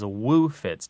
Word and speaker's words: woofits: Sometimes it's spelled and said as woo fits, W-O-O-F-I-T-S, and it just means a hangover.